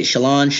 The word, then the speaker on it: Shallan: Shallan? – Shallan, yeah.